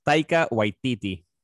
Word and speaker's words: Taika Waititi: He just finished wrapping up Thor, Love and Thunder, and they posted a dope-ass pic of Chris Odinson, and how do you say that director's name? – It's Taika Waititi.